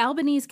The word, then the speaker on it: Albanese: And we said it correctly. – We did. – It's Albanese. Oh, yes, good.